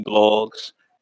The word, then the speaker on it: Bloggs: Bloggs. Spell it like it sounds: T H E W A D E B L O G G S. Uh, remember, I never go broke. My name got two G's.